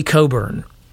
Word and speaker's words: Cockburn: and Yale graduate and author Leslie Coburn. And it's spelled Cockburn, but I'm just going to say it's Coburn because of Bruce Coburn, the artist.